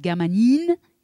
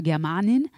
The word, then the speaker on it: Germanine: Germanine, c'est ça ne veut pas dire une femme germaine, comme on pourrait penser à une Marianne, mais Germanine, c'est comme aspirine, c'est le nom du magasin, du médicament. In, à la fin, c'est comme, voilà, c'est comme pour aspirine, donc ça se prononce Germanine, et pas Germanine.